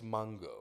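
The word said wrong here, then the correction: Mongeau: or something. – No, it's Mojo. – But there's an M. What Mojo. Is it, Charleston? It's Mojo? – Yes, the N is silent.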